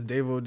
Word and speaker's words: Daivo: My Instagram is Daivo Did It Tattoos.